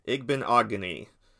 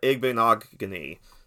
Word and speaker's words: Igbinoghene: And then another guy I want to look at, and I'm going to get his name right sooner rather than later. I'm going to make sure I get it right, and that is Noah Igbinoghene. Igbinoghene. There you go. There's your pronunciation lesson.